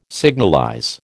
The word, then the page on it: signalize: American English